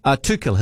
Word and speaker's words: Tuchel: Tuchel, his surname is pronounced.